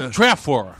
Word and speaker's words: Old Trafford: Old Trafford. – Oh, agora melhorou. Oh, tá bom a pronúncia, já que você depois dessa aula de... – Tá boa? Old Trafford tá bom.